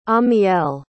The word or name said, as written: amiel